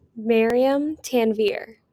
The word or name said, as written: Tanveer Maryam